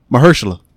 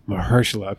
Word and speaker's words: Mahershala: Marsalis? – Yeah, Marcellus Wallace. – No. Dude, I butcher his name every time. How do you say his name? – Mahershala. – Mahershala, okay.